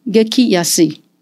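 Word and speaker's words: 激安: 于是，这个日本很有名的价格破坏王，因为“ 基安” 这两个字啊，我们也顺带补充一下，它的发音叫 “geki yasi”。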